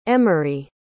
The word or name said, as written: emerie